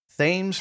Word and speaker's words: Thames: Verizon Business, Nokia, win Thames Freeport private 5G network deal in the United Kingdom.